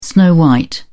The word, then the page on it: snow-white: British English